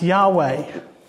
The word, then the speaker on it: Yahweh: The times where the small capital version of Lord appears means the holy name of God, which most scholars would think is pronounced Yahweh.